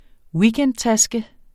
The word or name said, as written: weekendtaske